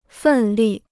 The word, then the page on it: 奋力: fèn lì